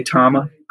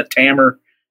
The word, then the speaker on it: Tama: And so they're not going to say Tama in Japan. And so when they say Tama, I say Tama. So I say Tama, but I'm cool. However, it's pronounced. I meet a lot of people that say Tama, Tammer, Tammer, you know, all kinds of great pronunciations that are Americanized and fine with me, you know.